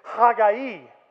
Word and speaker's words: Haggai: If you want to hear the literal Hebrew pronunciation is Haggai.